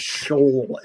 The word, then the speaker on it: Sheol: The others were down in what we reference as Sheol, S-H-O-E-L-E-O-L. Sorry, I've forgotten which. Doesn't matter. – E-O-L. – Okay. And yeah, because I usually pronounce it Sheol.